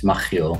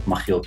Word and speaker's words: Machiel: It's a Dutch name. In Dutch, it's Machiel, Machiel Klerk.